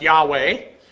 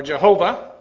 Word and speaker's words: Yahweh: D, the second primary term by which he revealed himself is Yehovah, sometimes pronounced Yahweh, or Jehovah. You'll see it alternately spelled that way. Some people transliterate the yod as a J, which is derived from the verb hayah, to become, and points to his existence or being.